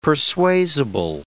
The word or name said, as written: persuasible